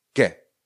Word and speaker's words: que: Jsou to slova jako kien, Q-U-I-E-N, kien, kien, kien, znamená to kdo. Nebo velmi typicky bude právě slovo ke. Ke. Ke se píše Q-U-E ke znamená to co, nechť, ať, kéž, používá se jako různá spojka, jako zvolání, budeme ho mít hodně často.